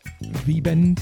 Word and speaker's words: VBND: And then we just had, before this, music from, I presume pronounced V-Bend, V-B-N-D, all lowercase, no vowels.